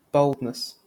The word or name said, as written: boldness